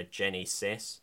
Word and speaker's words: Genisys: but it's Terminator Jenny Sis. – Oh yeah, yeah. – Jenny Sis spelled G-E-N-I-S-Y-S. Like, it's just like they can't spell the common word Genesis.